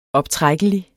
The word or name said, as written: optrækkelig